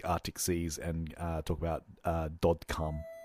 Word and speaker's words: Dod Kalm: We are talking about this week and Dod Kalm. It's how you would say it and pronounce it in the Norwegian. It's the Norwegian for dead calm, I'm assuming.